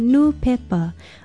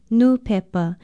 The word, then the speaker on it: nūpepa: Aloha, I'm Leilani Poliahu of Hawaii Public Radio, and our Hawaiian word of the day is nūpepa, a Hawaiian word that comes from the English word newspaper. It is spelled N-U-P-E-P-A with a stress over the first vowel, nūpepa.